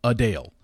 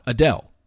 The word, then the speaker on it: Adele: Why, after all this time, everyone's basically called her Adele, why didn't she change and correct people then and say, no, it's Adele. The pronunciation spelled out U-H-D-A-L-E, Adele, not Adele.